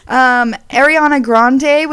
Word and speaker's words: Ariana Grande: Ariana Grande was named New Artist of the Year.